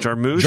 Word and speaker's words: Jarmusch: Jarmusch.